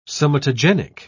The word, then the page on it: somatogenic: British English